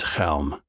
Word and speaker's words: Chelm: The name of the village is Chelm. You pronounce it like you've got something stuck in your throat.